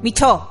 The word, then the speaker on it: Michaud: Oye, ¿cómo se pronuncia Michaud? Michaux? Michaud. Ah, muy francés, es un apellido francés.